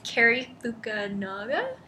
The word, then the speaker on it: Cary Fukunaga: It's Cary Fukunaga.